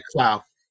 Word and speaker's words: Clough: Rob is Clough. – Clough? That's my bad. I'm gonna. – It can be pronounced about 12 different ways, so don't worry about it.